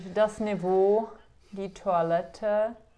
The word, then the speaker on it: Niveau: No, jako je pravda, že němčina používá hodně francouzských slov, takže jako třeba Niveau, vždycky já jsem se to učila jako to, jak se to píše, ale nevěděla jsem, jak se to pořádně vyslovuje, pak teda jsem zjistila, že je to Niveau,